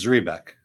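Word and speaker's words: Zrebiec: I believe it's Zrebiek.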